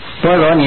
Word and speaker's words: 般若: 般若你念成般若了，好多的佛教经上的字了，你必须有人给你解说，你才知道它的含义。